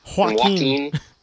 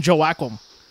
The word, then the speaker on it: Joaquin: Joaquin. Whereas like as Raju will not call him Joaquin, he'll always call him Joaquim.